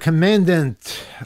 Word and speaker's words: Commandant: Former Coast Guard Commandant, that's how you pronounce that, Admiral Linda Fagan, the first woman to lead a branch of the U.S.